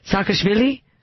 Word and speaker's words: Saakashvili: how do you say it, Saakashvili? – Yeah, that'll do.